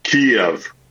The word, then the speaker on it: Kyiv: it is not as easy as simply saying Kyiv. It's not a single syllable city. It is Kyiv.